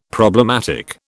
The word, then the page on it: problematic: British English